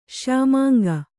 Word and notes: ಶ್ಯಾಮಾಂಗ: śyāmānga

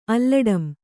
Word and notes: ಅಲ್ಲಡಂ: Kannada

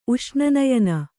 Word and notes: ಉಷ್ಣನಯನ: uṣṇanayana

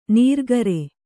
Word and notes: ನೀರ್ಗರೆ: nīrgare

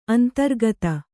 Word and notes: ಅಂತರ್ಗತ: antargata

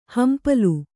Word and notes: ಹಂಪಲು: hampalu

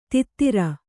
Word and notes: ತೈತ್ತಿರ: Kannada

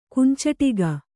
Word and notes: ಕುಂಚಿಟಿಗ: kunciṭiga

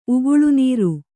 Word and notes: ಉಗುಳುನೀರು: uguḷunīru

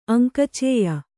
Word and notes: ಅಂಕಚೇಯ: Kannada

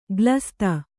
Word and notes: ಗ್ಲಸ್ತ: glasta

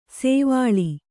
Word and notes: ಸೋವಳಿ: kannada

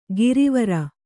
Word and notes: ಗರವರ: garavara

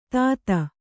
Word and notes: ತಾತ: tāta

